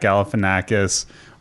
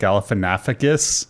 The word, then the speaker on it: Galifianakis: Like if you like Louis, it's like right up your alley because like first off, it's written by Louis C.K., Zach Galifianakis, or as I used to call him, Zach Galifianakis. I used to always put in that extra syllable and now I get tortured when I go back to old episodes and listen to it.